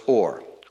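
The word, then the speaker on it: or (אור): These are both silent letters, only two silent letters in the alphabet. Aleph is silent and ayin is silent. Ayin means I. And it looks like a face with two eyes looking to the left. But both words are pronounced or.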